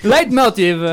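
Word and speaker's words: leitmotiv: no, non si dice così. Late Motive.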